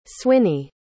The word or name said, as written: swinney